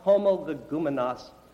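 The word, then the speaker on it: homologoumenos: The Greek word translated without controversy is pronounced homologumenos.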